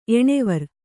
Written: ಎಣ್ವೊರ್

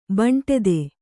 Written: ಬೆಂಡೆತ್ತು

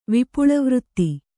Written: ವಿಪುಳವೃತ್ತಿ

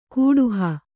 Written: ಕೂಡುಹ

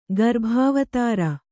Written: ಗರ್ಭಾವತಾರ

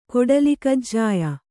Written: ಕೊಡಲಿಕಜ್ಜಾಯ

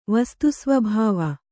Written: ವಸ್ತುಸ್ವಭಾವ

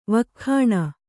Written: ವಕ್ಖಣೆ

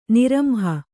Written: ನಿರಂಹ

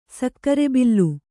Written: ಸಕ್ಕರೆಬಿಲ್ಲ

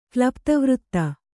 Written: ಕ್ಲಪ್ತವೃತ್ತ

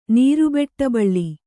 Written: ನೀರುಬೆಟ್ಟಬಳ್ಳಿ

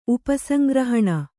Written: ಉಪಸಂಗ್ರಹಣ

